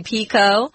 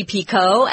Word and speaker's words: Picoult: Actually, Tom, when you were reading this, you did 2 pronunciations. I just wanted to tell everybody, this is her name. The only way you'd actually know how she pronounces it is to ask her yourself. So you can say Jodi Picoult or Jodi Picoult. They're both correct, actually. It's a French name. That's why we don't pronounce the LT.